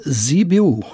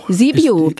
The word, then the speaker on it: Sibiu: Ja, Hermannstadt, Sibiu. – Sibiu, also wie man schreibt. – So wie man schreibt.